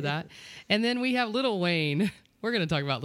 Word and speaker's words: Lil Wayne: It's Lil Wayne. – Lil. – Lil. – Lil. – Oh, Lil. – There's no T.